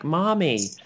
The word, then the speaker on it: Marmee: Um, and I had to Google that, and and what I learnt was in the in the original story they would have pronounced the R as an H, so it would have been like "mommy."